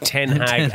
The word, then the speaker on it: Ten Hag: He was the Den Haag manager. – No, it's pronounced Ten Hag, and he's the Manchester United manager.